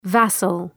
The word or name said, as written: vassal